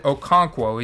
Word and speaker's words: Okonkwo: The role for Achigawim Okonkwo, oh dude, I'm probably butchering his name because I forgot how to say his name. His last name is said Okonkwo.